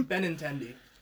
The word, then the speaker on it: Benintendi: Is that how you say his name? – Benintendi.